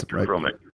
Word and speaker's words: electrochromic: We can do laminated glass, so we can meet Miami-Dade hurricane code requirements you said, um, see if I pronounce this right, electrochromatic. Is that what it is? – Uh, That's electrochromic. Right? – Electrochromic.